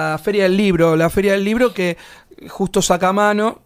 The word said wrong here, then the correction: Sacomano: Sacamano, si lo dije bien. Sacomano. Sacomano.